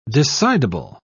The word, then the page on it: decidable: British English